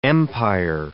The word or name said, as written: empire